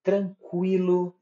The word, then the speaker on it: tranquilo: Exemplo: aguentar ambiguidade, consequência, delinquente, equino, frequência, linguiça, tranquilo, frequentar. Vocês percebem que em todos os us foram pronunciados. Fracamente, mas foram.